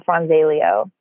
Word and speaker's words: Franzaglio: Nobody can ever say my last name right, because the way that it's spelled in Italian, it's like the G is silent. So it's Francesca Franzelio.